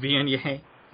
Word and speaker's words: Viognier: Um, all right, so um, we are going to move on, I guess, to um, what is commonly referred to as Vignier. Um, it's actually pronounced Vignier for those um, uh, for those who don't know.